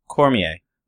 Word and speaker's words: Cormier: Now if you take how do you say, is it Cormier or Cormier? – Cormier.